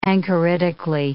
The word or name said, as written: anchoritically